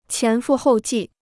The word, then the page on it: 前赴后继: qián fù hòu jì